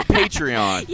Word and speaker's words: Patreon: And you've got a Patron. Am I saying it right? – I got some Patron. – Oh, yeah, Patreon.